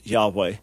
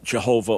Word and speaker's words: Yahweh: So even though I believe, can't say 100% definitively, but I believe that the proper pronunciation of God's name is Yahweh, and certainly not Jehovah, or as it would have been in Hebrew, Yehovah. I certainly don't believe that that's the right pronunciation.